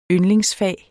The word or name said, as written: yndlingsfag